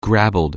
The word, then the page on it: gravelled: anglais